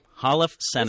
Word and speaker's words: Holofcener: And they would be The Past by Asghar Farhadi, which I'm probably pronouncing it correctly, and Nicole Holland Suffers Enough Said. I can't pronounce anyone's name. Holofcener.